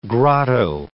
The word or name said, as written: grotto